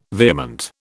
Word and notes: vehement: British English

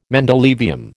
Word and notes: mendelevium: American English

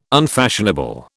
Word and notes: unfashionable: British English

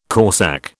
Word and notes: corsak: British English

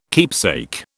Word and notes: keepsake: British English

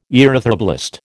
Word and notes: erythroblast: American English

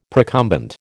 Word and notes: procumbent: American English

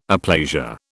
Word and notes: aplasia: British English

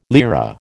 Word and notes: lira: American English

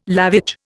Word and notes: lavage: Indian

